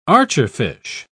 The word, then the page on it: archerfish: British English